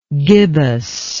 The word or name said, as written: gibbousness